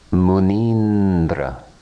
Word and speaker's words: Munindra: Vowel combinations which result in the long vowel sound E. E plus E equals E. Muni Indra. Muni Indra. Munindra. Munindra, the best of Munis, sages.